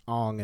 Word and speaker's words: Aang: But aside from the whitewashing element of the casting, the biggest casting faux pas to me was that our main character, Aang, who is pronounced Ong in this movie, was cast off of an audition video sent in showing off his martial arts abilities.